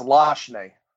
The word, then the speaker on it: Lachney: It's French. It's Lachney.